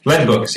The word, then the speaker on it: Lead Books: Am I pronouncing that? Is it Lead Books, right? – Lead Books, yeah.